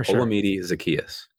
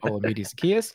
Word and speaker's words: Olamide Zaccheaus: Olamide Zaccheaus. – Thank you very much. I appreciate that. Olamide Zaccheaus.